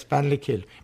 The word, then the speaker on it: Ballinakill: Yes, Ballinakil. B-A-L-L-I-N-A-K-I-L-L.